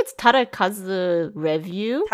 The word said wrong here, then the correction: Takarazuka: I'm sorry. It's Takarazuka.